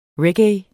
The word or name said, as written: reggae